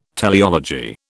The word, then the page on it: teleology: British English